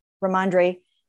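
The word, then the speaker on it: Rhamondre: And lo and behold, Ramondre, I don't know if I'm pronouncing that right. – Ramondre Stevenson comes in there and just runs over them.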